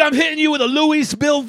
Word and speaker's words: Louisville: The bat, the bourbon, Louisville. You gotta leave the S. The S is silent. – Louisville.